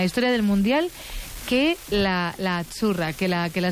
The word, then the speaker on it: azzurra: La Z no es la Z como decimos en catalán tan sonora, no. Ah, es azzurra. – Muy bonito, muy bonito. – Es como pizza.